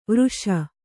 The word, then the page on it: ವರುಷ: varuṣa